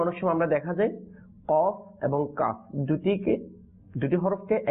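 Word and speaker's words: কাফ (كاف): অর্থাৎ কফ বলার সময় মুখটা একটু গোল হয়ে যাবে, আর কাফ বলার সময় মুখটা একটু খুলে যাবে।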